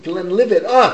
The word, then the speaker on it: Glenlivet: Glenlivet.